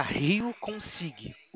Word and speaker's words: Rio Consig: Lá a senhora vai procurar Rio Consig. O G é mudo.